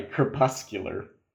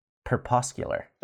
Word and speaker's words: crepuscular: Crepuscular. – Perpuscular.